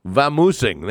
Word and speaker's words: vamoosing: I will be vamanosing. Is that how you say it? Vamanosing? Vamoosing. There you go.